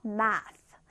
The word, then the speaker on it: math: math. math. 자, 지금 이 발음 기호에 보이는, 네, 요 표시가 있죠. 이게 th 발음은 th. 그래서 math. math가 되는 겁니다.